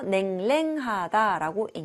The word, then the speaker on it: 냉랭하다: 냉이 두번 들어가는데, 처음에는 냉, 두 번째는 랭이라고 읽어서 냉랭하다라고 읽는 거예요.